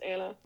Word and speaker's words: Anna: We were just like talking about Frozen, like why my name is pronounced Anna. – And it is Anna. It's not Anna.